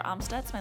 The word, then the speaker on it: Amstutz: And you can find all of my social media at Sandra Amstutz.